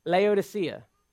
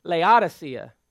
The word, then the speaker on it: Laodicea: Maybe you have too, because I always pronounced it Laodicea. Right? Any other Laodicea people out there? Pretty much the whole room. So apparently it's pronounced Laodicea, which sounds really weird when you first hear it that way, because you grow up hearing Laodicea.